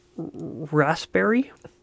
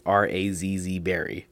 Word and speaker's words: raspberry: Raspberry. It's gotta be raspberry. – We say it like R-A-Z-Z berry.